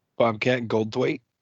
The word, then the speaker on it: Goldthwait: Bobcat, I think it's Goldthwat. – I don't even know. – Like, if you Google it, tell me how you're supposed to pronounce that fucking name. – Bobcat Goldthwait. – Goldthwait? You and your fucking, like, perfect pronunciation?